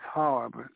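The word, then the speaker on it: harb (حرب): But the word for war in Arabic is harb. We spell that in English, H-A-R-B. H-A-R-B, that's harb, that's war.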